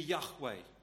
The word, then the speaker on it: Yahweh: We pronounce that now as we would look at it to be Yahweh.